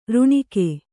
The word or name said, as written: ಋಣಿಕೆ